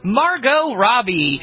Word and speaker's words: Margot Robbie: That is still in theaters right now, and that stars, and we're going to say her name correctly for once, Margot Robbie. Not Margot.